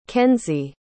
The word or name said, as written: kenzy